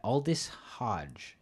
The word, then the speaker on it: Aldis Hodge: And Jim Brown is played by Aldis Hodge.